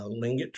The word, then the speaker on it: Tlingit: Uh, I'm going to butcher it, uh, Lingot tribe. It's spelled T L I N G I T.